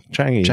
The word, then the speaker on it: Changi: Is it Shanji? Is that the way you pronounce it? Shanji? – Changi? Well, Changi.